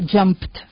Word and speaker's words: jumped: El pasado simple sería jumped. Se pronuncia como una T, pero se escribe ed, o sea, J-U-M-P, ed.